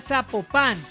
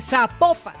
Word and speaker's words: Zapopan: Oiga, nos escriben, nos escriben los oyentes y que no se, no se pronuncia Zapopan, sino que se pronuncia Zapopan. – Ah, Zapopan. – Ah, bueno, Zapopan.